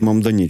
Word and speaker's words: Мамдані: Перемогу на виборах мера Нью-Йорка здобув 34-річний політик з охран Мамдані. Я сподіваюся, я правильно вимовив. – Мамдані, так. – Мамдані, чудово, так.